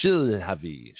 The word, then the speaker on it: Shilhavy: and I always mispronounce his name, Shilhavy? Let me spell it for you, S-H-I-L-H-A-V-Y.